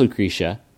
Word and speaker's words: Lucretiz: Her name is also spelled Lucretiz, and that's pronounced Lucretia, Maurice has informed us.